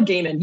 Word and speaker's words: Gaiman: Even though I was trying to, because you were like, is it Gaiman? And I was like, Gaiman. It's Neil Gaiman. You had it right.